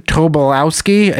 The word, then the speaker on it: Tobolowsky: That there is a scene one-on-one with two of the most 90s character actors of Stephen Tobolowsky.